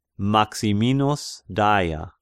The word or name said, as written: Maximinus Daia